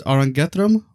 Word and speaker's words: Arangetram: And I believe it's pronounced Arangetram, right? – Yes, that's yes. Correct.